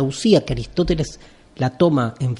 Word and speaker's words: ousia: Sustancia es una traducción latina de la palabra usía, se escribe ou, ousia, que en griego significa algo así como la identidad de algo.